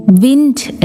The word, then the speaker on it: wind: WIND വിൻഡ് എന്ന് വായിച്ചാൽ കാറ്റും WIND വൈൻഡ് എന്ന് വായിച്ചാൽ ചുരുട്ടുക എന്നാണും അർത്ഥം.